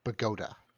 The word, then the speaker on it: Bogota: There is also a city in New Jersey that is spelled the same as the capital of Columbia, but is pronounced Bogota.